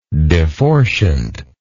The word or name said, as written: deforciant